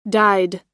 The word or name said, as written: dyed